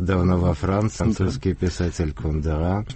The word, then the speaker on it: Кундера: И так это в новейшее время к этому прибавился, безусловно, Кундера, которого российские многие даже интеллектуалы предпочитают почему-то произносить с ударением на втором слоге, а некоторые на последнем. Ну, очевидно, от того, что он...